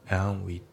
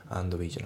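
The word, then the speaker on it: and we: であと発音ですけど、アンドの D が抜けますね。アンウィだね、アンドウィじゃなくてアンウィ。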